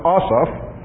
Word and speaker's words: Asaph: His name is Asaph. That's how it's pronounced, Asaph.